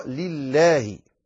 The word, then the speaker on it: لله: أيضا عندما تقول لله، وسيأتي إن شاء الله ذكر اللامات، عليك أن تنتبه لترقيق لام لله، يعني عندما يكون لفظ الجلالة مكسور اللام في البداية تقول لله، لا تقول لله، هذا خطأ، عليك أن تنطقها لله.